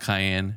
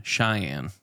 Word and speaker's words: Cheyenne: In 1892, a legendary army captain reluctantly agrees to escort a Cheyenne chief... No, not Cheyenne. Cheyenne would be the correct pronunciation.